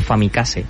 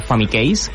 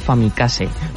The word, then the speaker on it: Famicase: A principis d'aquest any es va celebrar la Famicase, pronunciat així, no digueu Famicase, que s'enfaden des de Japó. És la Famicase, un certamen on els participants han de dissenyar doncs, aquestes etiquetes de jocs inventats per la Famicom.